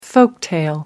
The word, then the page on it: folktale: British English